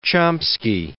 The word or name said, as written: Chomsky